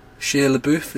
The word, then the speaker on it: Shia LaBeouf: Shia LaBeouf, is that how you pronounce his name? – Yeah, that's a good effort.